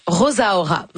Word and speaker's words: Rosaura: Premier EP assez court, à peine 18 minutes, 6 chansons de Talia Rosaura, où elle prononce Rosaura. Donc j'imagine que c'est comme ça qu'il faut le dire. – Hein? Ben, c'est comme Rosalie.